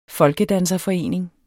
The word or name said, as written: folkedanserforening